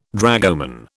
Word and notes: dragoman: British English

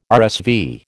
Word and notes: RSV: American English

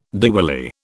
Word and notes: Diwali: British English

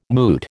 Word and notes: moot: American English

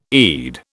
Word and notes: Eid: British English

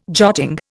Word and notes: jotting: Indian

